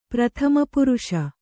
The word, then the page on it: ಪ್ರಥಮಪುರುಷ: prathama puruṣa